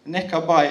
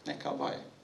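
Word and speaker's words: Nekabai (נקבה): Nekabai, er det skal uttales om. Nekabai.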